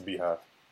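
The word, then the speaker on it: Beyhive: It's the Beehive. – I see folks spell it B-E-Y. – So yeah, they say B-E-Y, but it's the Beehive.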